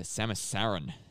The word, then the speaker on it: Samus Aran: Follows the bounty hunter Samus Aran, that's how you say it, is attacked by an ex-parasite while exploring the mysterious planet SR388.